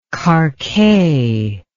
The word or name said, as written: karkheh